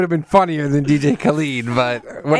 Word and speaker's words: Khaled: DJ Khaled. – Khaled.